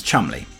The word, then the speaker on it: Cholmondeley: It's, it's pronounced Chumley.